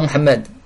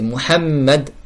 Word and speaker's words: Muhammad: Si la personne n'arrive pas à prononcer correctement « Muhammad » avec le « ha »,« Muhammad » est le mim doublé « Muhammad ». C'est pas Muhammad, c'est Muhammad. Shadda en arabe, c'est une lettre.